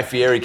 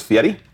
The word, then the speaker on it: Fieri: We just met Guy Fieri came up to us after the You mispronounced it? It's Fieri.